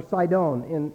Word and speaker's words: Sidon: Two of the most despicable cities in all of the Bible are the cities of Tyre and Sidon, or Sidon, as it's actually pronounced.